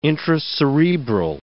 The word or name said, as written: intracerebral